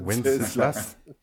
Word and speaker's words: Wenceslas: Vas-y, il y a combien de syllabes? – Venceslas. – Wenceslas. Wenceslas. Donc, ok, en gros, je vais jamais dire son nom.